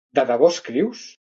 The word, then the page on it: escrius: Catalan